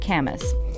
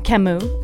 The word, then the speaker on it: Camus: Hearing the happy voice of Reza Rouhani talking about harmony, not melody, reminded me of this quote from Albert Camus." He says, Camus. – Oh, pardon me. Again, with the pronunciation.